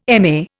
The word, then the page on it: MA: Indian